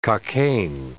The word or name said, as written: Cockaigne